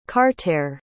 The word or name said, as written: cartere